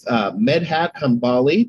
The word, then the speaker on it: Medhat Hanbali: I'm here with Medhat Hanbali.